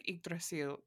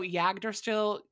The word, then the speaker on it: Yggdrasil: And I mean, I feel like we are also pronouncing it wrong because we're not pronouncing it like in Old Norse, like Yggdrasil. You have to say it spooky, I guess. But no, Yggdrasil. All of the sounds.